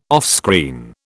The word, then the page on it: offscreen: British English